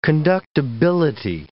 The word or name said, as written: conductibility